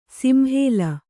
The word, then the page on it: ಸಿಂಹಲ: kannada